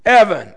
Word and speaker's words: Eben: Now it's spelled Eben, but it's pronounced Evan.